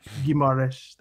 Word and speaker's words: Guimarães: Guimarães.